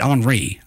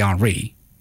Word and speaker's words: Henry: Is it Henry or is it Henri? I was imagining it'd be Henri, right? Haitian, you pronounce it. But let's just say Henry, because I'm from here.